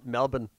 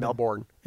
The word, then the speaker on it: Melbourne: Like Australia, they don't pronounce the letter R. – I think they don't in the middle. Yeah, they do at the end. – Yeah, he's like, where you were from? Melbourne. No, Melbourne. It's Melbourne.